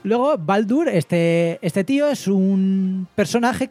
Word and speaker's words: Baldr: Baldur se escribe B-A-L-D-R. Yo lo traduzco como Baldur, o sea.